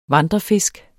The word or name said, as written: vandrefisk